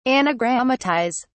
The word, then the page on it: anagrammatise: British English